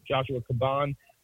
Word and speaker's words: Joshua Caban: So Joshua Caban, and I said it right because I heard him say it before.